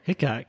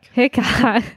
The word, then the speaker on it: Hickok: Wyatt Earp, Calamity Jane, and Wild Bill Hickox. – Hickok. – Hickok. – Yeah. – No plural.